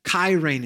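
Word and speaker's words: Kyrene (χαίρειν): And the standard greeting in the first century would have been chairein, Kyrene is how you pronounce it in the Greek.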